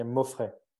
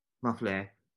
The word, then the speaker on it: Mauffrey: For Guillaume Moffi, am I pronouncing that correctly? – Yeah, Moffret. – Moffret.